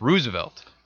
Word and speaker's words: Roosevelt: which actually I found out it's pronounced Roosevelt. – Roosevelt. Oh, so because it's Dutch.